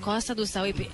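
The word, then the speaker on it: Costa do Sauípe: Les vamos a contar en detalle cómo empieza esta fiesta y cómo se va a desarrollar mañana paso a paso aquí en Costa Duzahuipe. ¿Lo dije bien? – Sí. Perfecto, Costa Duzahuipe.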